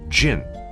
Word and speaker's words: 症: Слово «джен» произносится также в четвертом тоне, в переводе на русский это симптом болезни.